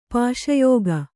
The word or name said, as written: ಪಾಶಯೋಗ